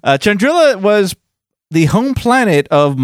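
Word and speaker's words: Chandrila: So Toryn Farr, her story starts, well, with her birth, but she was born and raised on the planet Chandrilla, I think is how you say it. Chandrilla? Yeah, Chandrilla. There's no Y next to that A.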